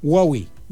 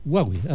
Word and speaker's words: Huawei: Huawei. – Huawei, así es.